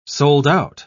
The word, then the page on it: sold-out: British English